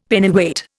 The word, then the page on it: Pennyweight: Indian